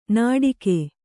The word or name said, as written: ನಟಿಕೆ